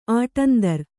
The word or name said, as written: ಆಟಂದರ್